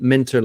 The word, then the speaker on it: Minter: Minter like winter with an M.